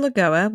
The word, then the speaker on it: Lagoa: Am I mispronouncing that? Lagoa? – Barbara Lagoa was appointed to the Florida Supreme Court by Ron DeSantis, and he speaks incredibly highly of her.